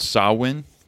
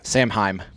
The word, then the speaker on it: Samhain: They have a festival called, it's called Samhain, but it's not spelled like that at all. S-A-M-H-A. – Samhain. – Samhain, basically, is out, but it's Samhain, yeah.